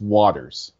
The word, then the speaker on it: Waters: I grew up in suburban Philadelphia, and in Philadelphia and the surrounding suburbs, sometimes Waters is pronounced Waters, almost like WOODERS, but it's Waters, water with an S.